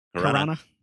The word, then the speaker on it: Caruana: Caruana.